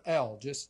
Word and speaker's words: El: God, in Genesis 1:1, is the Hebrew word El. It's spelled just like it sounds, E-L, El. Now, there's a suffix on the name there, and it's Ohim.